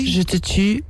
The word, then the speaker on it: Je te tue: How do I pronounce this? Je te tue? – Tui? Je te tue.